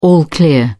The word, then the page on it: all clear: British English